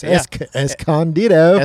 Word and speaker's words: Escondido: Escondido.